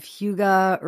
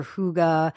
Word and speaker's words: hygge: I think the Danes would appreciate a pronunciation of hygge or hygge.